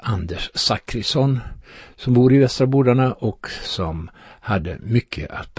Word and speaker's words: Västra Bodarna: Det har vi i Västra Bodarna. Ni ska få veta allt om Västra Bodarna. Eller Västra Bordane kanske vi ska säga. Eh, beroende på om hur man vill uttala namnet.